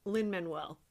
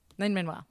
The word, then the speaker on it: Lin-Manuel: I always am like, is it Manuel? Like I feel like I don't know how to pronounce the middle name. – I think I've heard him say Lin-Manuel. – Lin-Manuel.